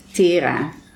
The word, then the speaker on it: Tera: Yeah, it's a Dutch name, so it's Tera.